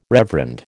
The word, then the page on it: reverend: American English